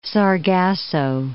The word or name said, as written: Sargasso Sea